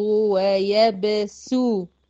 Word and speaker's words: يبسط: يبقى يقبض ويبسو، سو مش صاد، سين ضمة، يبقى هنطق سين مضمومة.